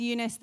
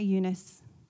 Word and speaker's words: Eunice: Now, I know sometimes Eunice, the Greek, can be pronounced Eunike. I'm going to say Eunice just because I'm Australian.